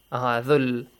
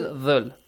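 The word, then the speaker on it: ذو: Zul. Zul. No, it's not zul. It's just dhul.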